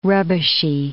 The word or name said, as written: rubbishy